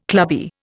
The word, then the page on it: clubby: Indian